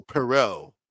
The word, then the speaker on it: Poirot: Hercule Poirot.